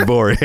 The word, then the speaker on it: Gborie: David Gborie, everybody. – Hey. – First of all, your name is spelled G-B-O-R-I-E. Did I get it right? But the G is silent? – The G is silent.